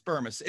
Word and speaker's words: Bermas: It's Bermas. It sounds like an I, it's an A at the end, and it's kind of a berm ass without that second S.